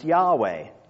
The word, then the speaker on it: Yahweh: Most of you have probably heard it pronounced Yahweh.